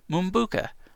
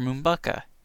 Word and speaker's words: Mumbuca: and a virtual currency called Mumbuca, or Mumbuca. It's spelled M-U-M-B-U-C-A, Mike, Uncle Mike, Bravo, Uncle Charlie, Alpha.